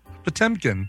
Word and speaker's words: Potemkin: How would you say it? Potemkin? – Potemkin. – Potemkin. Yeah. Oh, shit. – It's the Potemkin.